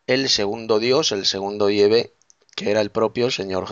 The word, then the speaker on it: Yahvé: Parece que Yahvé, pues parece ser que es la verdadera pronunciación del nombre hebreo, del Dios de Israel, Yahvé. En hebreo arcaico, pues parece ser que es Yahvé.